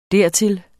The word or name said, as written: dertil